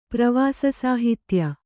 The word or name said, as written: ಪ್ರವಾಸಸಾಹಿತ್ಯ